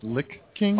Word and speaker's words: Lich King: I believe it's pronounced Lich King.